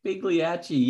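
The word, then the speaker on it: Pagliacci: Pigliacci, yeah. Pigliacci, yeah. Italian pronunciation, yeah.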